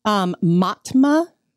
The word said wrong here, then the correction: Mamta: Koffelbot, I believe. Matma. And it's all over social media, everywhere, her picture. And I'm telling you. – I'm reading Mamta.